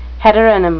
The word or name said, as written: heteronym